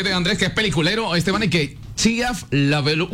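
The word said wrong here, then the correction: LaBeouf: ¿Lo dije mal? – Chiaf Lefou. – Lefou.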